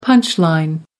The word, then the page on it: punch line: British English